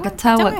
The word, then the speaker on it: Cachagua: Como en Col Colchagua, Cachagua, ¿cómo se dice Cachagua, Cachagua, Cachagua.